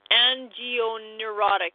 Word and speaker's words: angioneurotic: such as an angenerotic edema. I don't even know if I pronounced that right. Angioneurotic, there we go, edema, including peripheral and facial edema, bronchial spasms in individuals without an allergic history.